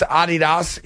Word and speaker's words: Adidas: It's pronounced Adidas in Germany, where the Nazis were that invented the company.